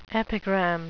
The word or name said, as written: epigram